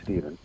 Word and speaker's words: Stephen: Well, it's, most people actually call me Steve, but as an author name, it has to be Steven. – Oh, but spelled with a PH, sorry, it's not phonetic.